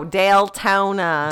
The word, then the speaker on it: Deltona: And that's Deltona is, she's not mispronouncing Daytona. – No, Deltona. – D-E-L.